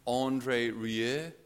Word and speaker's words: Rieu: I think it's pronounced Andre Rie, not Andrew Rio. Andrew Rio. That's the Limerick version of Andre Rieu.